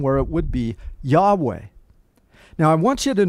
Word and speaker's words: Yahweh: No one knows the pronunciation of this name. More than likely, though, we call him Yahweh. There's no W sound in the Hebrew.